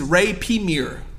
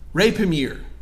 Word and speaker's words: rapiemur: and the Latin phrase is rapimir. Rapimir. And it's spelled R-A-P-I-E-M-U-R.